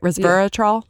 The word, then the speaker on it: resveratrol: Resveratrol? – Yeah.